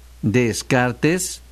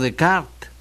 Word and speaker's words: Descartes: que se pronuncian tal cual Descartes, no Descartes, y Honorato de Balzac.